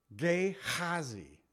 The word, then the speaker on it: Gehazi: Then he said to Gehazi, actually it's Gehazi is how you say it. I'm just having a hard time saying it. In the Hebrew, Gehazi, gird up thy loins and take my staff in thy hand and go thy way.